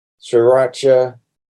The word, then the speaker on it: sriracha: But over the past year or two, I've discovered, how do you pronounce it? Is it sriracha sauce? – Sriracha sauce, yeah.